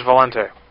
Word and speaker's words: Valente: This is Valente. – Would you please spell it? – V-A-L-E-N-T-E.